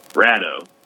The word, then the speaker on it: Colorado: Rado. It's Rado. – Rado. – Rado. – Rado. – Prove it. – That's how y'all say it over there? – Rado.